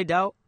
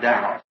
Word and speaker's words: Daugh: Nali, mzwale Dow, wani utu anji? Ani talima. – Not really in his name, he's a German. D-A-U-G-H, and I also pronounce it Dow. – Chole luli, kalibizo la hae, kimu German, D-A-U-G-H, mini libizanga kuli Dow.